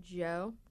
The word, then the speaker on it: Djo: D-J-O, but it's just pronounced Joe.